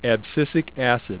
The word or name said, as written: abscisic acid (ABA)